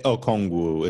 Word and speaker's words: Okongwu: There's obviously like in the case of Onyonwu, is that how you say it? – Okongwu. It's Onyeka Okongwu.